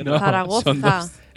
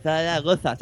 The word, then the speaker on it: Zaragoza: Zaragoza. Siempre con Z, Zaragoza, saca la lengua. – Eh, Zaragoza. – Así, así. Entonces, para la lista de para que vayan a recoger sus premios a Zaragoza,